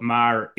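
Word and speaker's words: Meagher: Can you, as an Irishman, tell us how we're supposed to pronounce Thomas Marr? – Thomas Francis Marr. So as it, you can get a couple of different pronunciations even within Ireland of it. So it's, I wouldn't be too hard on yourselves in relation to pronunciation. But you usually Thomas Francis Marr.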